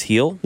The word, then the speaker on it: Gil: Luis Gil? – Yeah, it's a silent G.